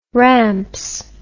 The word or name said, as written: ramps